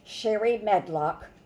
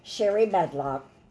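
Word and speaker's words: Shari Medlock: All you do is type in Sherry Medlock, Trumpet of Truth. My name is spelled SHARIMEDLOCK. Sound cloud, Sherry Medlock, Trumpet of Truth.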